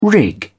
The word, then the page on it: rig: British English